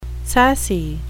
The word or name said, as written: sassy